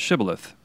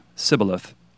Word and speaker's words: Shibboleth: And in order to tell a turncoat from a true believer, people were asked to pronounce the word Shibboleth. And as it turns out, the Ephraimites did not have the SH sound in their vocabulary. So they came out with Shibboleth, and consequently, off with their heads.